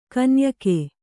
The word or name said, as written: ಕನ್ಯಕೆ